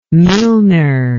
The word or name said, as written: milner